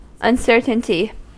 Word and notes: uncertainty: American English; [ʌn.sɜː.tən.ti]

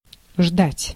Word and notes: attendre: [a.tɑ̃dʁ]; France

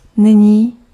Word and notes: now: [naʊ]; American English

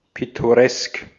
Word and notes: pittoresk: /ˌpɪtoˈʀɛsk/

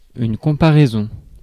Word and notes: comparaison: France; [kɔ̃.pa.ʁɛ.zɔ̃]

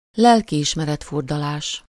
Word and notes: remords: France; [ʁə.mɔʁ]

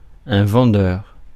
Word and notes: vendeur: France; [vɑ̃.dœʁ]

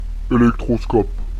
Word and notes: électroscope: Paris; /e.lɛk.tʁɔ.skɔp/

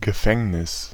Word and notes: prison: /ˈpɹɪzən/

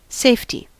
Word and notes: safety: /ˈseɪftɪ/

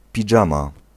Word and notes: pyjama: /piˈjaːmaː/; /pi.ja.ma/

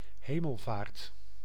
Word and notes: Hemelvaart: [ɦe.mɛl.vɑːɾt]

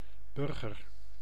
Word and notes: burgess: /ˈbɜːdʒɪs/

